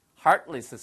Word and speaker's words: heartless: Heartless 쓰시면 되겠어요. 아시겠죠? 발음이 heartless라고 안 들리고요. Heart 하고 옆구리를 누가 살짝 쓰겠지 좀 아프니까. Heartless 이렇게 쓰시면 되겠습니다.